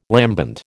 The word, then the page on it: lambent: American English